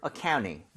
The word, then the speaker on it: accounting: 이렇게 NT가 모음 사이에 껴있으면 T가 탈락할 수 있는 옵션이 생겨요. 제가 옵션이라고 했기 때문에 이거는 넣어도 되고 빼도 됩니다. So, accounting or accounting. 둘 다 괜찮아요.